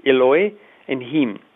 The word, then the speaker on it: Elohim: is Elohim is two syllables. It's Eloi and Him.